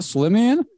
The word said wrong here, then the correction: Psyllium: Psyllium husk. – Psyllium husk.